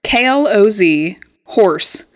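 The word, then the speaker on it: clotheshorse: Claw's horse? – KLOZ horse. – Oh, is it clothes? – Is Oh, I hate being this stupid.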